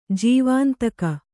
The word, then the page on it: ಜೀವಾಂತಕ: Kannada